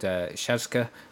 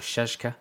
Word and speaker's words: Czaszka: It's from a record label called Shazka Records.